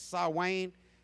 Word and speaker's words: Samhain: They went yeah, we're offering sacrifices to Lord Samhain, or some people pronounce it Sawain, tonight, and you need to give up one of your sheep.